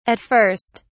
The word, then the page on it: at first: British English